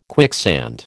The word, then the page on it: quicksand: American English